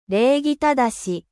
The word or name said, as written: 礼儀正